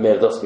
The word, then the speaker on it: مرداس: تلفظی که دکتر خالق مرداس روی همون هست دیگه تلفظ مرداس من مرداس میگم روی نام عربیه که من اعتقاد دارم این نام نام عربیه این نام عربیش مرداسه